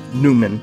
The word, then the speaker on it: Neumann: from Eastern Europe, St. John Neumann, whose last name has also been pronounced Newman.